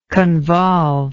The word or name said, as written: convolvement